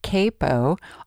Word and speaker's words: capo: That funny-looking device is a capo, C-A-P-O. It's generally pronounced capo, although way back when I was learning to play guitar, my instructor, who was from England, pronounced it capo. So, to eliminate any confusion, capo, capo, I decided to do a little research on the pronunciation, and as it turns out, both ways are correct.